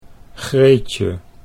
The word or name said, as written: Greetje